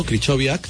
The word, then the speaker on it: Krychowiak: Vamos a escuchar si te parece, a uno de los jugadores que habló ayer tras el partido, Krychowiak, que dijo que iban a intentar acabar primeros de grupo.